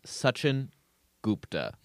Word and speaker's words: Sachin: The pronunciation of this man's name is Sachin Gupta. There are probably a lot of people who are going to say Sachin or Sachin, but I looked up online and this Indian lady said it was Sachin Gupta.